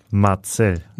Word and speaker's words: Marcel: in dieses Podcast Abenteuer starten, wie wär's, wenn du lernen würdest, wie ich eigentlich wirklich richtig ausgesprochen werde mit der Vorname, ne? Marcel.